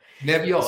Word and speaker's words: Nebbiolo: Nebbiolo, so, it's awesome, love it.